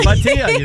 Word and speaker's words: Bhatia: In third place, Akshay Bhatia is 2 strokes back right now. Back to you guys. – Very good. I had to say his name last night. Yes, Bhatia, you nailed it.